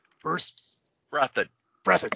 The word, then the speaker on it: Breathed: Breathed, thank you.